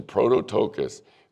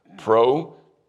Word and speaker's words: prototokos: Write down that word in your Bible in Colossians and write it down here in Revelation, prototokos, P-R-O-T-O-T-O-K-O-S. And it's pronounced just like it looks, prototokos, prototokos.